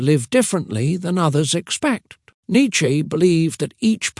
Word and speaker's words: Nietzsche: Philosophy Window, Nietzsche, and the Fire Within. Friedrich Nietzsche, pronounced Nietzsche, was a philosopher with wild ideas and an even wilder mustache.